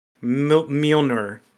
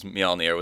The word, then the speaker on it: Mjolnir: I'm going to take Mjolnir. – That Mew one. Mew. – Mew. I can't say it. Pronounce it like the J is a Y.